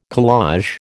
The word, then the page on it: collage: American English